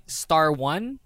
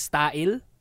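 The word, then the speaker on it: Star1: You normally people like if you're new to the Sistar fandom, you would say Star 1, but it's actually pronounced as Style because it's Star and then 1, Style.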